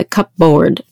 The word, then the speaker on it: cupboard: But yeah, that P is in there, the cupboard. You don't say it. Go to the cupboard. Yes. No. – No, silent.